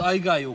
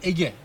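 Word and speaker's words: Aigai: Aslında modern Yunanca'da Ege diye okunuyor bu. Evet, evet. Biz Aygai okuyoruz. Yani bu Erasmus okunuşu Aygai. Şimdi Yunanlı gelse buraya Ege diye okuyacak.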